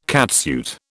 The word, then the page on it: catsuit: British English